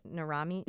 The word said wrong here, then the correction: Nirrimi: Nirrimi. Nirrimi.